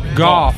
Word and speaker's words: Gough: All right, so Go Hazard, or it's Goff. Goff Hazard is spelled G-O-U-G-H. – It's kind of like cough, but it's a G. – Yeah, but Doe is spelled D-O-U-G-H.